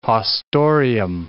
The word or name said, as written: haustorium